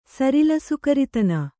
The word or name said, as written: ಸರಿಲಸುಕರಿತನ